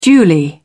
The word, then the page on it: duly: British English